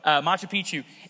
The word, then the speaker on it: Machu Picchu: Did I say that right? Machu Picchu? Yeah, that's pretty close.